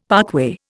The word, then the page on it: parkway: Indian